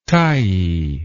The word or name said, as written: tyee